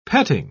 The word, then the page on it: petting: British English